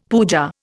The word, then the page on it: pooja: Indian